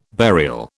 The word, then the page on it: burial: British English